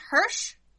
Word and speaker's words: Hirsch: Okay, and next order of business is, for Gravity Falls creator, I make sure I pronounce his name right, Alex Hirsch? – Yep.